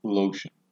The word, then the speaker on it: Loeschen: Erica A. Lotion, and that's spelled L-O-E-S-C-H-E-N. Erica A. Lotion, 86, of Paxson, died at 10:02 a.m.